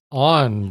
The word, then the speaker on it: Onn: And this is a new Google TV streaming stick made specifically for Walmart, because it is Walmart's on brand, which is spelled O-N-N.